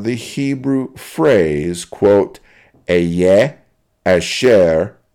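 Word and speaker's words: asher (אֲשֶׁר): The original Hebrew word here is pronounced asher.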